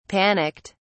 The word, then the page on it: panicked: British English